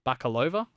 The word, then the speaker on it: Bakalova: I mean, we should talk about Tuta, the daughter, played by Maria Bakalova, I believe her name. That's how you pronounce it.